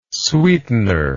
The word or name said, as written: sweetener